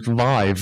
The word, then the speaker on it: Vive: That sounds like the only one people use, which is Vive.